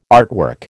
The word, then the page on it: artwork: American English